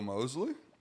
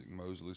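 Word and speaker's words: Moseley: My name's Will Mosley. You can find me at Will Mosley Music. Mosley spelled with 2 E's, M-O-S-E-L-E-Y.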